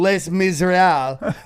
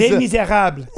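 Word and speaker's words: Les Misérables: This guy's doing Les Miserables as he fucking tries. – Les Miserables. Say it right. Les Fuck Miserables.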